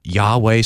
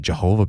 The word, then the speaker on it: Yahweh: And in the Old Testament, that's Yahweh. Sometimes people would pronounce it Jehovah, but Yahweh in the Old Testament, that's a name that refers to Father and Son and Holy Spirit.